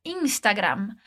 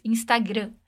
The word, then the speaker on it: Instagram: Um é no fatta, que tem dois t's, e depois em como a gente pronuncia a palavra Instagram. A gente não anasala o som no final como em português, Instagram, mas pronuncia o A bem aberto e fecha os lábios quando falar o M.